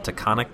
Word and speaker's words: Taconic: By the way, I have no idea if I'm pronouncing that right. Taconic? Taconic? It's confusing. – It's Taconic.